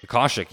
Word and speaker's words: Akashic: Akashic record. I hope I'm pronouncing that correctly. – Oh, Akashic, yes.